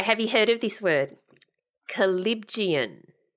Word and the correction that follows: callipygian: No, I've said that wrong. – You've said it wrong. – Callipygian. C-A-L-L-I-P-Y-G-I-A-N.